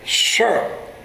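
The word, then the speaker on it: Sur (צור): That's the Hebrew word, T-S-U-R. The T is silent, Sur.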